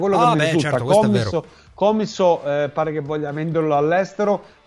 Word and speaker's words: Commisso: Manuel Moroni mi comunica che Rocco Commisso, perché non è commisso, lo pronuncia correttamente, quindi finiamo dicendo Commisso.